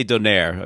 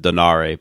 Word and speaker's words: Donaire: So getting back to the World Boxing Super Series for a second, I mean, obviously both the Inoue-Donaire, I always say Donaire. I mean, I've heard people pronounce it Donare, but I'm going to say Donaire.